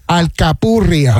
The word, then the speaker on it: alcapurria: Alcapurria.